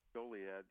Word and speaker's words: Goliad: But it sounds like just as fervent a battle cry back then would have been, do we pronounce it Goliad? – Goliad, yes.